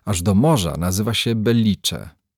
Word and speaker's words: Belice: Belice z akcentem nad i. Później, gdy nastąpiło trzęsienie ziemi, w ogólnokrajowej telewizji sycylijską gminę przemianowano na Belice. Owo charakterystyczne dla Rzymian cofnięcie akcentu nad e stało się synonimem pecha. Tak oto, utraciwszy pamięć, dolina straciła też swoją nazwę. Dzisiaj nawet Sycylijczycy, kiedy mówią o tej dolinie, nie kładą już akcentu we właściwym miejscu, na sposób arabski.